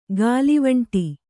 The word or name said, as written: ಗಾಲಿವಂಟಿ